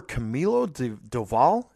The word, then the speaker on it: Camilo Doval: Camilo Doval.